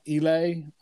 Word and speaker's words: Islay: It's pronounced Islay, Islay, and they're very pungent, peaty, smoky scotches that can be a little peppery tasting.